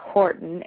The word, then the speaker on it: Horton: I'm on Facebook, Athena Horton, H-O-R-T-O-N. Athena is spelled A-T-H-E-N-A.